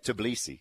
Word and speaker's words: Tbilisi: That's how you say it. – Tbilisi.